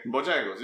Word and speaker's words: Bojangles: Is it called Bojangles? I said that right? Okay. Bojangles. – Yeah, that's Bojangles.